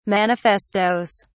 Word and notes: manifestoes: British English

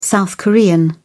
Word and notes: south korean: British English

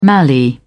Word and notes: mallee: British English

